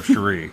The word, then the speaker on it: Cherie: Cherie.